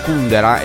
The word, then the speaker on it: Kundera: Ecco Antonello Venditti qui su Radio Latte Miele, cita anche Milan Kundera, e sulla U l'accento corretto, perché negli anni Ottanta tutti quelli che volevano fare un po' i fighi leggevano L'insostenibile leggerezza dell'estero di Milan Kundera.